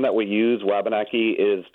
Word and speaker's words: Wabanaki: Eileen sends an email saying we are not pronouncing Wabanaki as the natives pronounce it. She says we need to be saying wah as in a baby's cry.